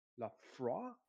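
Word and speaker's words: Laphroaig: It's L A P H O L A P H R O I D Lafroy.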